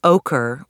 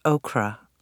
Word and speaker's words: ochre: I always thought the color ochre was pronounced okra. I got that wrong. Okra is a vegetable. Ochre is a color.